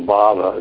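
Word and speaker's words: bhāva: So bhava, it's just with a long A, is a state of being, or a mentality or an emotion.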